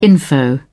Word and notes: info: British English

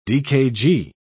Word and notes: dkg: British English

